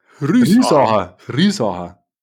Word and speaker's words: Riesacher: Riesacher. Betonung auf der ersten Silbe und dann schön ausklingen lassen.